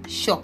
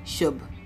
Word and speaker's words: shop: So too often people, instead of pronouncing the p in that word, they replace the p with b. So you hear shub. I'm not talking about market women. I'm talking about people who are well educated, pronouncing the word shop as shub.